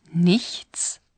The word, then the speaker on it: nichts: Scusi, ich nix verstehen. – ¿Será porque no ha pronunciado correctamente la palabra nada? Nichts. – No es eso. En el lenguaje coloquial se pronuncia así con frecuencia.